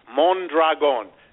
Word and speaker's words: Mondragon: Mondragon. I'll spell it. M as in mother, M-O-N as in nothing, and then dragon, like the creature from the deep. Mondragon.